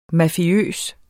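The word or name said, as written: MAFIØST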